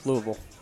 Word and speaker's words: Louisville: I mean, that was the closest Louisville was for the rest of the day. – You said that correctly. Louisville.